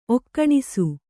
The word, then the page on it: ಒಕ್ಕಣಿಸು: okkaṇisu